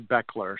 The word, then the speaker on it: Bechler: And then my last name looks like Bechler. Everybody pronounces it Belcher, but it's really Bechler.